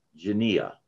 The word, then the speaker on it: genea: The word, if I'm saying it correctly, the Greek word genea, G-E-N-E-A. That's the word that has been translated generation. Okay. It's used 10 times in Matthew. And 34 times in the New Testament, genea, that's how I'll pronounce it. Now, it never means race, R-A-C-E.